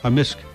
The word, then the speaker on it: amisk: Amisk. – Amisk. – Amisk. – So the K can be silent, but generally it's...